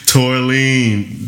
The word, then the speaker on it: tourmaline: A tortellini style. – A tortellini. – Yeah. – Tourmaline. Wait, now I'm saying it right.